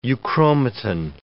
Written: euchromatin

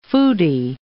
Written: foodie

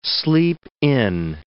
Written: sleep-in

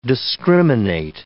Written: discriminate

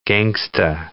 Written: gangsta

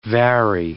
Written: vary

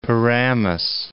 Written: Paramus

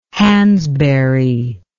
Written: Hansberry

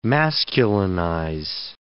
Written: masculinize

